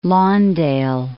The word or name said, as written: Lawndale